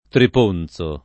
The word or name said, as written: Triponzo